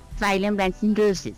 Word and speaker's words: Cyril Russell: Eso, pronuncie bien, hombre. – Cyril Russell. – Exacto, gracias, muy amable.